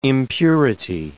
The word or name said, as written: impurity